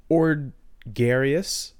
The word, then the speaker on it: Ordgarius: The genus is Ordgarius. It's like a gregarious. It's a gregarious Ord.